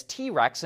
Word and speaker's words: TRX: As you know, Ram just came out with its answer to the Ford Raptor, the TRX, which they pronounce as T Rex internally, and it's a serious off road machine, and we recently got to drive one.